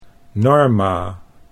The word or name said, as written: Norma